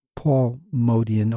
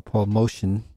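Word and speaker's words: Motian: That comes from Paul Modian, or Paul Motion. Some people pronounce it different ways. I always thought it was Paul Modian.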